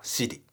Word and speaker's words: Siri: サアップルの Siri、はい、これあの残念ながら日本語のあの発音で言えばちょっとあの、まあ、お尻の尻と同じ発音になってしまいますね。